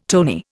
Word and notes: tony: Indian

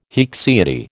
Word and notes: haecceity: American English

